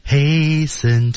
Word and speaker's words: hasten: Do not t 失去爆破，失去爆破，注意，do not t 没有这个音，hasten 中的 t 也是不发音的，大家注意。然后 to be 也是不发音的。 Do not hasten to bid。